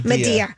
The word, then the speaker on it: Madea: Madea.